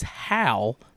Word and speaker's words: Hal: But it is Hal, like the computer in Space Odyssey.